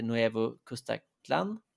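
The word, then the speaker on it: Nuevo Cuscatlán: stad som heter Nuevo Custaclan.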